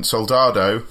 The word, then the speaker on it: Soldado: Soldado, uh, also known as Sicario 2.